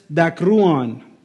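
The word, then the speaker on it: Dakruon (δάκρυον): Dakruon. Dakruon. Everyone say it. Dakruon. Well, you don't have to roll your R's, sorry. Dakruon. Yeah, there you go. No rolling R's.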